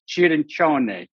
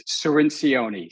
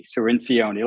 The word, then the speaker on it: Cirincione: In Italiano, it's Cirincione, but here it's Cirincione, Cirincione, like minestrone.